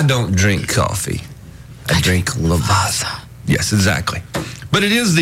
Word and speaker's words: Lavazza: Lavazza. – How do you just say it if you're trying to be cool? – Lavazza.